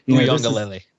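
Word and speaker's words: Uiagalelei: But Uyongalele.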